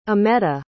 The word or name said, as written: ametta